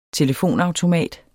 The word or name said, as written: telefonautomat